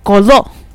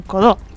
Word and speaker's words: Codó: Hay un pueblo, un playero en Brasil, pero que se llama Codó. O sea, con acento en la última O, Codó. – Codó.